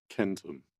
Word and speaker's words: centum: Although we usually read the C-E-N-T Latin syllable as cent in English, like centurion, centimeter, century, and so on, the original pronunciation was with a hard C, centum.